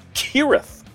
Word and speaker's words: Cirith: Now, for those who aren't sufficiently dorky, Cirith Ungol, that's a hard k sound, is a mountain pass from Tolkien's Lord of the Rings. Cirith, not Cirith.